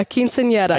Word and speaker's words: quinceañera: I was talking with your mother before, and we had talked about your quinceañera, which I know I'm going to get railed over for pronouncing like a typical white person. – No, it was fine. It's quinceañera. – Can you pronounce it for me? – Yeah, quinceañera. – I can't do that, but okay.